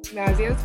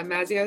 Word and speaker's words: Mazzeo: Mazzeo's good. Yeah, Mazzeo is probably the most American way. And then in Italian, it's Mazzeo.